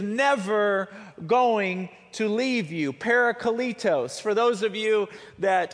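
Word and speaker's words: parakletos (παράκλητος): Now, if you look up that word comforter in the Greek to find out its original translation, the word is actually pronounced parakletos.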